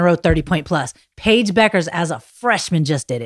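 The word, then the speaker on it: Bueckers: A lot of people think her name is Bukers because of the way it's spelled. It's Paige Beckers.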